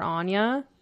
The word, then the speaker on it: Aine: Yeah, that's like if me and Stuart have a little girl, we're naming her Anya, but it's spelled A-I-N-E because it's Celtic, like it's Gaelic.